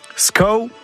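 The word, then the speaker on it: skav: Skao, bon, ça s'écrit S-K-A-V, c'est comme ça, c'est le sureau.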